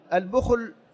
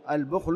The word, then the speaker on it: البخل: قوله من البخل، البخل هكذا كما تسمعون نطقه، البخل بضم الباء وإسكان الخاء، وفيه لغات، فيه لغات غير هذه لكنه مشهور، البخل والبخل إلى آخره، لكن البخل هو أشهر.